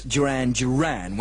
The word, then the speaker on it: Duran Duran: I said Durran Durran and Planet Earth at number 26, when, of course, it should be, as everybody knows, Duran Duran.